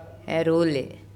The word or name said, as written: Eroule